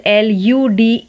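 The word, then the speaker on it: elude: first word की spelling है ए डबल एल यू डी ई अल्यूड और दूसरे वर्ड की स्पेलिंग है E L U D E एल्यूड अल्यूड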